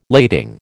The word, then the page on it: lading: American English